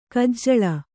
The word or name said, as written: ಕಜ್ಜಾಲಿ